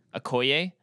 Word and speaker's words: Okoye: Okoye? That's a guess on how to pronounce it. – That sounds right.